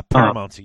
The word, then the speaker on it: paramountcy: Yeah, so if you go through that, you end up in a paramountcy. I'm not sure I'm saying that correctly. – Yeah, paramountcy, you got it.